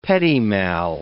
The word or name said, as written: petit mal